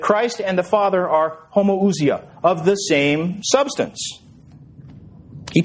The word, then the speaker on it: homoousia: The word is homoousia. I'll spell it for you. Again, I don't have a transparency. Homoousia. This is Greek. H-O-M-O-O-U-S-I-A. H-O-M-O-O-U-S-I-A. Homoousia.